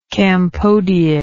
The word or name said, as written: campodeid